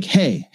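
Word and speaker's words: hej: Uh, oh, Jonas is an update you can pronounce hedge like, hey, hey.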